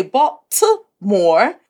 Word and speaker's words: Baltimore: But I've also lived in Baltimore, or we say Baltimore with a T, but everyone from Baltimore pronounces it with a D.